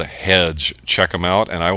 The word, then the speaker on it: Headge: The group is called Hedge. It's spelled H-E-A-D-G-E.